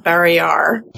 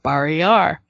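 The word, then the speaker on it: Barrayar: I've always said Barriar. I don't know what the audiobooks do. – The audiobooks pronounce it Barriar. – Then I shall do so forthwith. Barriar.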